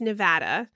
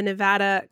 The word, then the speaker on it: Nevada: It's Nevada, not Nevada.